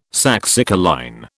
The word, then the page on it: saxicoline: British English